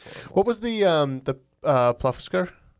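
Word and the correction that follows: Plokkfiskur: Yeah, plockfiskur.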